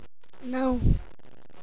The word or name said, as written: não